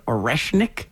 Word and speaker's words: Oreshnik: And they used a nuclear-capable hypersonic ballistic missile called an Oreshnik. – Yeah. – Is that how you say it? – I believe so.